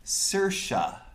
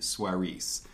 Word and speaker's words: Saoirse: pronounces her name... Saoirse, as in inertia, as she's been known to say in interviews. Saoirse is Irish for freedom, and she admits that she got a chuckle over my pronunciation, Suarez.